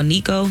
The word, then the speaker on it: Neekko: You can follow me personally at Your Girl Nico. Your Girl is spelled as it should be. Nico is spelled N-E-E-K-K-O.